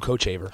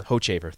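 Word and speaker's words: Hochevar: Luke, I never remember how to say his last name. Hochaver? – Luke Hochaver. – Hochaver, thank you.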